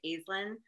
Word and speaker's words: Aislinn: Yep, it's Aislinn, and you pronounced it perfectly.